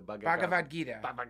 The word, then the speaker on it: Bhagavad Gita: He gave me the book I can never pronounce correctly, the Bhagavad Gita.